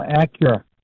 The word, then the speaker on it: Acura: No, Acura. A-C-U-R-A. – A-C-U-R-A.